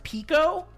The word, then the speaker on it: PECO: Molecule's core technology, which they pronounce PECO, P-E-C-O, okay.